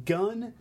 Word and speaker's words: Gun: Spelling my name phonetically in English is hard, so just go with gun. Pronounce like the thing that goes bang.